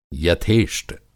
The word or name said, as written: यथेष्ट